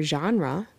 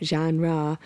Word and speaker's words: genre: So like yesterday when I was teaching with my students, I was talking about how the word genre is a French word. That's why it doesn't follow English rule patterns. And it was funny because the one student thought it should have been a short A, like she pointed out, genre. She felt like there should have been two A's in it because if it had followed English pattern.